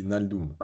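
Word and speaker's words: Wijnaldum: C'est Van Naldum, c'est ça on prononce Naldum.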